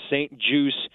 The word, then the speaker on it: St. Juste: They've got Benjamin St. Juste, but it's spelled J-U-S-T-E, so a little French-Canadian there, but it's pronounced St. Juste.